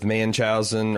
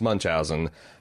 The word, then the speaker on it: Munchausen: Angela, on your wagon says, I've heard of mothers with Manchausen or Munchausen, because I got a correction on the pronunciation of Munchausen. – Mm-hmm. – I think it's, I'm doing a Manchausen. I think that's more of an accent than a mispronunciation, but still, it's Munchausen.